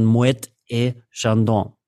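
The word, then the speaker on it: Moët et Chandon: Also du sagst dann Moete et Chandon so ist der, die richtige Aussprache, wenn du das, den ganzen Begriff, und wenn du den Rest dann weglässt, würdest du es trotzdem so aussprechen, als ob der Rest dann noch käme, ja, und deswegen würde ich immer Moet sagen.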